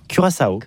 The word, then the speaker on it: Curaçao: Donc comment on prononce ça? – Curaçao. – Ah oui, Curaçao, j'aimais bien.